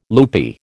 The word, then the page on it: loopy: American English